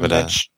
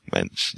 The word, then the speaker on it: Moench: When I know John doesn't like Doug Moench or Monk or however you pronounce his name. – Doug Moench. – Moench, yeah. But I like his work with Kelly Jones, so I've been trying to track down as many of those issues as I can.